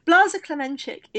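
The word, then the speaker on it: Blaža Klemenčič: Blaza Klemenčík, is that how you pronounce it? – Yeah, Blaza Klemenčík is a rider who's just been popped for EPO.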